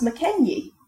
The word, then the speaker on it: Mackenzie: So the surname Mackenzie now almost universally takes the Z sound at the end. – That was my name before I was Cairn Cross. – Okay. – Mackenzie. – But originally it would have been pronounced Mackenyie.